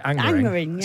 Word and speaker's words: Angmering: We've had another text from Angmering in West Sussex. – Angmering, that's not far from me. – Is it not? Have I said it right? – Angmering, yes.